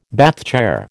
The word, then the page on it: Bath chair: American English